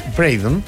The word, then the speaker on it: Braden: Pero nadie, se escribe Braden, y es Braden, y nadie decía voy a la Braden Cooper Company, o nadie decía voy a...